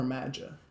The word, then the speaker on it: MAGA: Is it MAGA or MAGA? I really don't know how to say it. – It's MAGA. – MAGA? – Yeah.